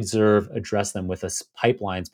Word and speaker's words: Pypelines: If you pronounce it Pipelines, Pipelines.